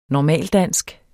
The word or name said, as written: normaldansk